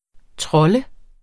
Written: TROLLE

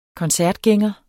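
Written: koncertgænger

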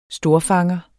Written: storfanger

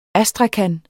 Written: astrakan

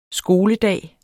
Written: skoledag

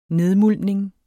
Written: nedmuldning

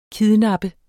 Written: kidnappe

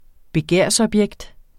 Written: begærsobjekt

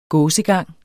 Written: gåsegang

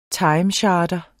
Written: timecharter

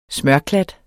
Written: smørklat

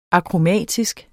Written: akromatisk

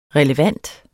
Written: relevant

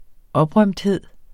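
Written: OPRØMTHED

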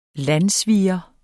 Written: landssviger

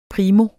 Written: primo

